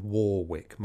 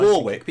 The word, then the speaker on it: Warwick: W A No, it's spelled Warwick, but you say Warwick.